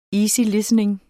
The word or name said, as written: easy